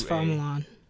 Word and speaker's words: Fa Mulan: Is it Fa Mulan? I mean, it's I believe it's Fa Mulan. – But they pronounce it F. Okay, Fa Mulan, she's, you know, trying to honor her family, and by honoring her family, she must get married.